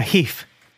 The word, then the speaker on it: jefe: A heaf.